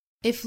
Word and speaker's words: aye: 这个 A-Y-E 我们可能不熟，这个词的发音就是 I，一会儿我们会讲它是一个古英语。